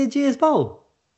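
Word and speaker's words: Ageas Bowl: Aegeus Bowl. – It's not Aegeus, is it? You're lying to me. – It is. It's the Aegeus Bowl.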